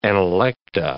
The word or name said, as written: analecta